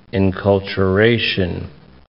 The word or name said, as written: enculturation